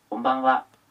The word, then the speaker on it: Konbanwa: Konbanwa.